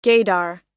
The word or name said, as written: gaydar